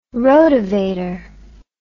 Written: rotavator

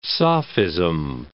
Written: sophism

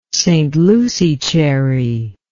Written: St Lucie cherry

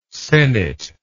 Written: sennett

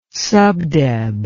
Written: subdeb

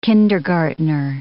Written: kindergartner

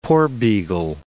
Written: porbeagle

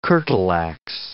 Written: curtal ax

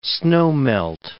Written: snowmelt

